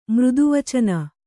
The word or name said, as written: ಮೃದುವಚನ